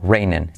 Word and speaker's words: raining: Y lo, que decimos, la R de raining que incluye una U, reinen, it's not reinen. Y la I como E, reinen, it's not reinen, it's not reinen.